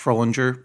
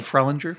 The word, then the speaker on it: Fruhlinger: By Josh Frohlinger, or I don't know how to pronounce his last name. – Seems about right. – Frohlinger? Frohlinger? Yeah. F-R-U-H-L-I-N-G-E-R.